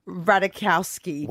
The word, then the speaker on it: Ratajkowski: And the biggest theory is that it was because he was avoiding his two exes, Olivia Wilde and Emily Ratajkowski.